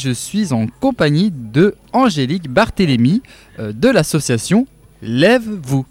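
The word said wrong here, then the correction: Levez-vous: C'est bien ça, je l'ai bien prononcé. – Lèvez-vous. – Lèvez-vous, superbe.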